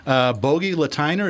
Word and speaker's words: Bogi Lateiner: Our first guest is, hopefully I'm pronouncing this right, Bogi Lateiner.